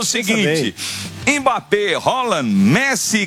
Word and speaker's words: Haaland: Mbappé, Haaland, que não é Haaland, viu? Ele ensinou. Foi ele que ensinou. Ele falou: a pronúncia do meu nome é Haaland.